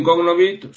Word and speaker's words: cognovit: Aventem cognovit son dos verbos distintos, ¿eh? – Pues cognovit. – Cognovit, efectivamente. Cognovit, de hecho, sería la pronunciación, ¿eh? La O es larga.